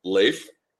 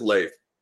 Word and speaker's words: Leif: And so it is Leif, L-A-Y-F, in terms of actual pronunciation. So it is Leif.